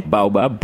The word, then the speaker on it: baobab: Baobab, bui. We call it bui. Bui, yeah. Baobab, yes.